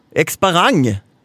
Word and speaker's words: Exparang: Exparans. – Exparang. – Exparang. – Tänk dig en boomerang på slutet och exp i början, som expose och expand och allt som är bra börjar på Exparang.